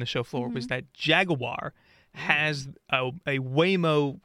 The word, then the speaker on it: Jaguar: Jaguar is how they say it in the commercial.